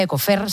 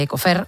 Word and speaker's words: Ecofire: Vamos a hablar con Ecofire, se pronuncia Ecofer, se dice Ecofer, un agente extintor orgánico capaz de frenar el fuego actuando como barrera térmica.